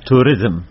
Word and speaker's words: توریزم: نظر وای کا سوال وای یو بس دا تروریزم په مناسب خبرې دي کنه؟ – د تروریزم نه، د توریزم بلیش تلفظ کې فرق ته خیر دی.